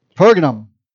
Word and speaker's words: Pergamum: Pergamum. Thank you. Missed a syllable in there. See, when there are too many syllables, I just try to remove them. Pergamum.